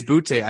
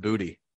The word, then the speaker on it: Boutte: So also, I don't think it's Boutte. I think it is pronounced Booty. – Is it Booty? – It is pronounced. I looked it up. I'm pretty sure, like 90% sure it's Booty.